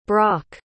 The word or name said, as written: braque